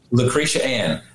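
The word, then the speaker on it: Lecretia Ann: Lucretia Ann. – That's right. – It's spelled L-E-C-R-E-T-I-A.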